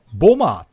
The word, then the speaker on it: bomber: B-O-M-B-E-R って書くじゃないですか。 – うん、これ英語発音すると、うん、間にある B は発音しないんですよ。だから、ボマーって言うんですよね。